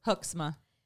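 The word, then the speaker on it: Hoksema: You want to know how to pronounce a good Dutch name? – But how? – Hoxma. – Yeah, that actually makes more sense than what I said.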